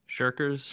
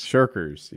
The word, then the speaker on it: Shirkers: Shirkers? Shirkers? – Shirkers. – Shirkers.